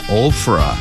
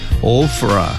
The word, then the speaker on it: Ophra: 자, 먼저 오브라를 영어로 이렇게 발음합니다. Ophra. Ophra. PH가 있어요. F 소리가 납니다.